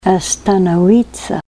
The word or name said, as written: astanawitsa